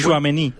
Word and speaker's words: Tchouaméni: Tchouaméni.